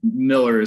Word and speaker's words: Mueller: So if you're on the inside, then you'll know that it's Cecil Miller, and it's hard for everybody because, you know, Miller is spelled Mueller. It's just how our German family has always said it.